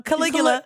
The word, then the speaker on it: Caligula: Caligula sounds like chlamydia. – Yeah. – Arugula, but no, Caligula.